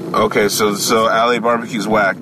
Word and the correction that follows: La Barbecue: We call it La. – La. Maybe, oh. – Yeah, that's how they pronounce it. Like, it's La Barbecue.